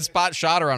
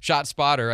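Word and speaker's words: ShotSpotter: I always want to say it wrong. I said Spot Shotter on a promo one time. Anyway, Shot Spotter.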